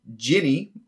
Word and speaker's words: djinn: Okay, let's go into our genie breakdowns then. I will go first. I will touch on the djinn first. So djinn, pronounced either ginny or genie.